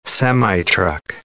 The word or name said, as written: semitruck